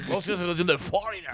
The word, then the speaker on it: Foreigner: ¿Cómo se pronuncia, señor? – Foreigner. – ¡Oh, no! ¡Foreigner! ¡Qué bonito habla usted, señor!